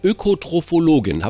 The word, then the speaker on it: Ökotrophologin: Sagen Sie, Sie sind ja Ökotrophologin. Habe ich das richtig ausgesprochen? – Das haben Sie richtig ausgesprochen, genau.